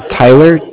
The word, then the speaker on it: Tyler: My name is Tyler, T-Y-L-E-R. – T-Y-L-E-R, okay.